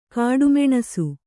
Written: ಕಡುಮುನಿಸು

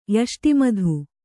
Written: ಯಷ್ಟಿಮಧು

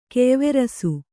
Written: ಕೆಯ್ವೆರಸು

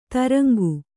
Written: ದ್ರಂಗ

